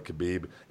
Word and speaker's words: Khabib: But again, a lot of that's going to depend on what happens with uh, Khabib, and it's pronounced actually. There's two. It, they, they said it's pronounced Hubby, but he goes. They go by both.